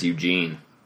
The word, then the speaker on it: Ugin: So, do you say Ujin, not Ugin? – I do say Ujin. – I think the proper pronunciation is Eugene. That's the one that's accepted in a lot of circles.